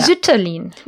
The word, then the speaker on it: Sütterlin: Zütterlin. Yeah. – Zütterlin.